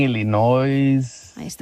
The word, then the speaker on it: Illinois: Cuando yo estaba allá, yo pronunciaba Illinois. – Illinois. – Cuando estoy aquí en California, que tengo más de 30 años de vivir acá ya, 30 años, yo digo Illinois. Pero cuando estaba allá, yo decía Illinois.